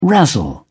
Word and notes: razzle: British English

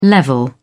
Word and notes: level: American English

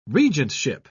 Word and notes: regentship: British English

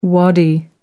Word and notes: wadi: American English

